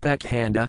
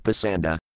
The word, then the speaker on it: Pakhanda: The word Pakhanda used in this verse is sometimes pronounced Pasanda.